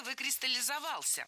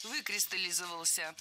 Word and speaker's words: выкристаллизовался: Было сказано «выкристаллизовался», но это неверно. Правильный вариант – «выкристаллизовался». Ударение падает на первый слог, на приставку «вы».